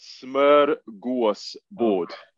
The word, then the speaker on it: smorgasbord: We're going to briefly touch on our last phase of training, which was pronounced Smorgasbrog. – You haven't butchered that at all. – Go on, tell me how it's pronounced. – Smorgasbord. – So I was nowhere near.